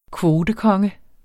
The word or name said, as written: kvotekonge